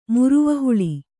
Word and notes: ಮುರುವನಹುಳಿ: muruvana huḷi

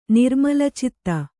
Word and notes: ನಿರ್ಮಲಚಿತ್ತೆ: nirmala citte

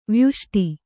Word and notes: ವ್ಯಷ್ಟಿ: vyaṣṭi

